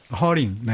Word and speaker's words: 허링: 하링이라고 그러죠, 보통. 네? 하링. – 허링. 네. – 어우, 발음. 네. 아, 네. 허링이.